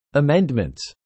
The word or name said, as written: amendments